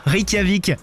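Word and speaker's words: Reykjavík: Reykjavik.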